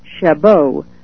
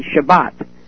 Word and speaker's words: Chabot: His name is Congressman Steve Chabot, C-H-A-B-O-T. I'm sort of giving it the French pronunciation. I'm not sure that I'm pronouncing it correctly. It may be Shabbat, but I sort of call it Chabot.